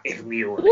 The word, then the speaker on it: Hermione: Senza neanche la H, Ermione. – Io non sto... – Con la E.